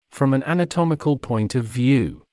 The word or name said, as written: from an anatomical point of view